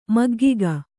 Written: ಮಗ್ಗುಗೆ

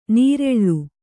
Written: ನೀರೆಳ್ಳು

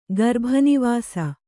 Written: ಗರ್ಭನಿವಾಸ

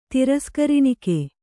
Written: ತಿರಸ್ಕರಿಣಿಕೆ

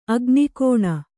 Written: ಅಗ್ನಿಗಣ್ಣು

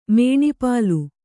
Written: ಮೇಣಿಪಾಲು

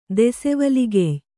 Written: ದೆಸೆವಲಿಗೆಯ್

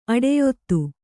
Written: ಅಡಿಯೊತ್ತು

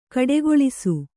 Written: ಗೂಡಗೊಳಿಸು

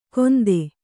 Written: ಗೊಂದೆ

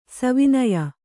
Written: ಸವಿನಯ

